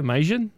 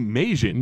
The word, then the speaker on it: Meijin: Now, in Japan, he's also known as Takahashi Meijin. How do you say that? Meijin? – Takahashi Meijin. – Meijin. And this is based off of an arcade game.